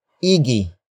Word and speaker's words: igi: Pag-igihan is spelled as P-A-G hyphen I-G-I-H-A-N. It is a command to do something well. Pag-igihan comes from the root word igi. Igi. Igi. Which means orderliness or excellence.